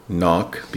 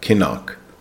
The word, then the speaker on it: knock: Number five is knock, K-N-O-C-K. Knock, be careful, the K is silent, so it's not kinnock, it's knock.